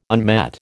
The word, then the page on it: unmet: American English